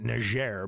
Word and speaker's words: Niger: And there were four servicemen who were killed, and they were special forces, and they were killed in the country that I've always pronounced Niger, but they've been calling it Niger because that's the French pronunciation.